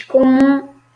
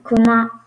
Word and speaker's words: commun: Tem também um problema de pronúncia de certas palavras, como a palavra commun, que você diz com acento brasileiro, você diz comum, porém não é comum, é commun em français.